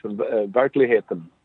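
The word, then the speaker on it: Verkligheten: So I'm probably going to mispronounce it here, so apologies, but I think it's Verklighetten. Does that sound about right? – Close enough. Verklighetten. – Oh, gosh. Verklighetten.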